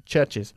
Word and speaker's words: churches: Y hay que pronunciar esta es como is. ¿Ok? Benches, churches. Lo mismo con watch. One watch, two watches. ¿Ok? – All right.